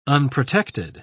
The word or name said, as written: unprotected